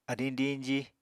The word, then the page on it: adiñ diñji: Apatani